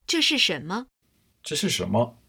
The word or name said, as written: zhe shi shenme